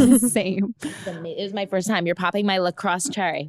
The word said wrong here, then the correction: LaCroix: What is it called? – LaCroix. – LaCroix. – LaCroix cherry. – LaCroix. That's what you're supposed to pronounce it as. LaCroix.